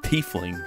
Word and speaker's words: Tiefling: Trifling. Tiefling? – I think it's Tiefling.